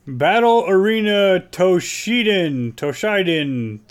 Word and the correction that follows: Toshinden: T-O-S-H-I-N. – Toshinden.